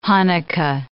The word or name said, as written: Hanukkah